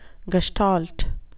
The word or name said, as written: gestalt